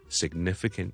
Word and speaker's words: significant: 亦都是有这个读法，所以 sig, sig, sig，但我 g 出来之前呢就做了个 n 音， so significant, significant, significant，就不好呢将它读成 significant，不是 sine，不是 sine，是 sine, sine, significant。